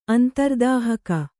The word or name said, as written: ಅಂತರ್ದಾಹಕ